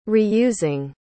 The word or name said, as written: reusing